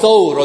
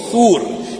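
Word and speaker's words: Thawr: This cave is known the cave of Thor or the cave of Thur, depending upon how you want to pronounce it.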